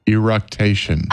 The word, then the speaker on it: eructation: Ergonduction. Ergonduction? How do you say it? Ergonduction. What is it called? Ergonduction. Can you say it? What is it called? Help us, sister. – Eructation.